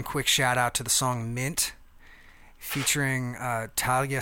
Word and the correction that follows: Taliba: Safaya. Is that it? Yeah. – It's Taliba.